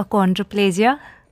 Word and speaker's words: achondroplasia: Sure, it's a achondroplasia.